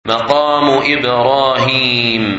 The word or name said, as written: Maqam Ibrahim